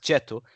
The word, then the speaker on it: Cetto: Mauro Cetto, I think it's pronounced Cheto, um, centre back, long haired, bit sort of gingery long hair.